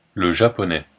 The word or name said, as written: le japonais